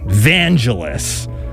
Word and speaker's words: Vangelis: We're going to begin the next set with the Greek electronic progressive ambient jazz pop rockin' orchestral music composer Vangelis. His name is pronounced like evangelist, but without the initial E or the final T.